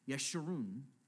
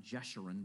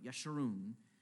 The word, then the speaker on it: Yeshurun: And then this renaming, almost like a nickname, and a sweet sense of irony, Yeshurun, that's actually how you pronounce it, not Jeshurun, but Yeshurun, which means literally upright one.